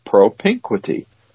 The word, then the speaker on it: propinquity: That's it. Propinquity. Emphasis on the second syllable.